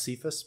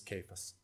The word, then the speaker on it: Cephas: Cephas, sometimes pronounced Cephas, but when I looked it up this week, yeah, the correct pronunciation is Cephas.